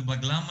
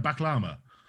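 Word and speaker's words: bağlama: How do you pronounce it? A baklama? – Baglama, yeah.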